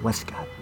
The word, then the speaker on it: waistcoat: Technically pronounced Westcott, but anyway.